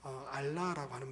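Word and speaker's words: 알라라고 (עֹלָה): 이 번제는 이렇게 알라라고 하는 말입니다. 그 히브리어로요 발음을 그렇게 하는데요.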